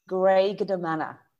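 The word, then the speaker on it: Graiguenamanagh: And yes, it's Greignamana. And it's spelt, let me just tell you this, Greignamana.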